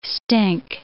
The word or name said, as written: stink